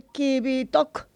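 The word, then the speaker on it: Qivittoq: A ver si lo digo bien. El Kivitok. – Correcto, lo has dicho muy bien.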